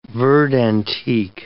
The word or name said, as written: verde antique